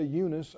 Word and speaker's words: Eunice: When I call to remembrance the unfeigned faith that's in you, which dwelt first in your grandmother Lois and your mother, I know some people say Eunice, I think it's pronounced Eunice, and I'm persuaded that in you also.